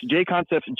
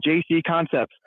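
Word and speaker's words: JConcepts: People who pronounce J Concepts, JC Concepts. People who still drink Bud Light.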